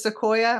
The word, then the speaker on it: Sequoia: Another character that I loved was, is it Sequoia? Am I pronouncing it correctly? – Yes, exactly.